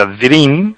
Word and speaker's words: Dream: Esta es la palabra inglesa que se pronuncia Dream, entonces el producto se llama Relagel Dream de Marnis.